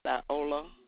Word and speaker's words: Viola: My name is Viola Gaucher. – Could you spell it? – V-I-O-L-A G-O-S-H-A. – You said V-I-L-O-A? – V-I-O-L-A.